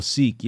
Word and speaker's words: Sikh: How you say it? Sikh? – Oh, Sikh, yeah.